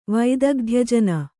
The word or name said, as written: ವೈದಗ್ಧ್ಯಜನ